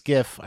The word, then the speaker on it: GIF: The guy who says that no, it's actually pronounced Jif. I'm sorry, dude, it's Gif.